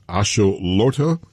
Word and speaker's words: Axolotl: It's the, for the World Cup, it is a salamander, and it's pronounced Axolotl, and it's the, yeah. I guess you would call it the, what, the mascot for the World Cup, but it has caused a bit of a problem. What is the controversy? – Yeah, well, your pronunciation was kind of spot on, so no worries about that.